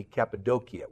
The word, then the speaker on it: Cappadocia: That's how they say it in Turkey, Cappadocia. We say Cappadocia.